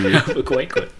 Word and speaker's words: Albuquerque: Sorry to our fans who live in Albuquerque. – Albuquerque. – Hey, you look how it's spelled. You look how it's spelled. – Yeah, it's a weird spelling.